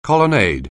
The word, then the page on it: colonnade: anglais